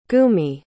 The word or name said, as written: gumi